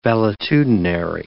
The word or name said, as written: valetudinary